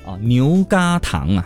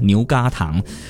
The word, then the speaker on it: 牛轧糖: 正确的规范读音应该是这个叫嘎嘎牛轧糖啊，是吗？也谢谢哥哥 9465 的让我知道齁，牛轧第二声齁，嘎嘎牛轧糖，原本是一个来模拟法语或英语中的那个 nougat，对不对？所以好，我们就现在很多人习惯性的把它念作牛轧糖这样子。